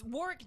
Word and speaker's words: Warwick: No, I'm just saying Warwick is how Warwick Davis pronounces his name.